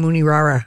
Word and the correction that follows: Rooney Mara: Yeah, I knew what you were talking about. – Yeah, Rooney Mara.